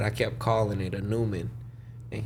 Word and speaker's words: Neumann: I had bought a TLM 103, and it's a Neumann microphone, but it's red, like in English pronounced Neumann, like. Mm. N-E-U-M-A-N-N or some shit like that.